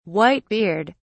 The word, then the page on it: white beard: British English